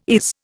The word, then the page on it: is: Indian